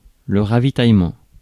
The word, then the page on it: ravitaillement: [ʁa.vi.taj.mɑ̃]; France